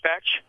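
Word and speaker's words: Bach: Spelled B-O-C-H, but he preferred his name to be pronounced Batch.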